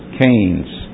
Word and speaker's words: Keynes: John Maynard Keynes. K-E-Y-N-E-S, and that's pronounced as if it were spelled C-A-N-C-A-I-N-E-S. Keynes. John Maynard Keynes.